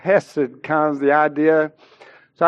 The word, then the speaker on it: Hesed: This particular word for loving kindness is the Hebrew word Hesed, and when you say it correctly,